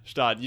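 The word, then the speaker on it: Gstaad: In Stag, is that right? – Yeah, Stad, I think. – Stad, yeah. – Yeah, Stad. You don't pronounce the G.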